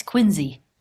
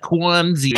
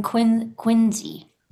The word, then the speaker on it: Quincy: It's probably like Quincy is the real pronunciation, but like the trashy Boston pronunciation is like, Quincy or whatever. – So how can our friend in Quincy manage their mental health as a self-employed person?